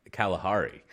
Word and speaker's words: Kalahari: I think at the Calamari? I always say it wrong, but it's some Kalahari.